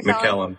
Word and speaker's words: McKellen: McKinnon? – Ian McKellen.